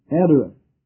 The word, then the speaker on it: adereth: The word, first of all, the word garment here is translated from the Hebrew word, which is pronounced in English, adereth.